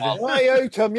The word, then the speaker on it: Iotum: Iotum, yes. Rhymes with Grotum.